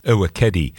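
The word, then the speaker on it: Ewekedi: Mbassi is spelled M-B-A-S-S-I. Coco Mbassi comes from a brand new album from her called Uwekedi. I'll spell that too. It's E-W-E-K-E-D-I, Uwekedi.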